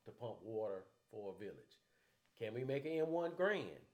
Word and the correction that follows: Garand: Garand, did I say it wrong? Correct. – Garand. – Okay, thank you. To be used. – Or Garand. Sorry.